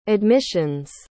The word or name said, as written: admissions